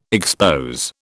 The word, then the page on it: expose: British English